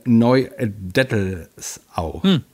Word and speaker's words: Neudettelsau: Oktober in 91564 Neudettelsau. – So spricht man das nämlich aus, ne? – Ja, ist immer die Frage, wo das S hinkommt.